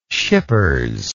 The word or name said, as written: Schippers